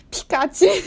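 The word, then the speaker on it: 光宙: 对，听看就这个名字看起来是很正常的名字，但是它就读成皮卡丘。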